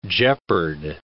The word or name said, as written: jeopard